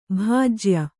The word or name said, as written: ಭೋಜ್ಯ